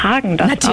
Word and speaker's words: Häagen-Dazs: Hallo, ich bin von Häagen-Dazs und unsere Eiscreme-Marke spricht man Häagen-Dazs aus, ohne den Umlaut, der wird im Skandinavischen nicht mitgesprochen.